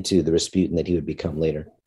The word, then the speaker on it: Rasputin: And Rasputin, in Russian pronounced Rasputin, is a fairly common surname in that part of Siberia.